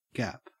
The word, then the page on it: gap: Australian English